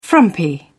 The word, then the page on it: frumpy: British English